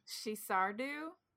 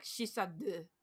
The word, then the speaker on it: Sinsar Dubh: It's spelled Sinsar. So it looks like it should be Sinsar, but it is not. – Yes. – I know it is she in the beginning, at least.